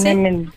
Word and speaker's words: Tiananmen: Tiananmen.